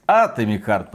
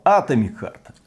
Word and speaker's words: Atomic Heart: Правильно говорить Atomic Heart, потому что слово атом, внимание, блин, пришло с греческого языка. И ударение на буквочку А там ставилось. Поэтому Atomic Heart, Atomic Heart.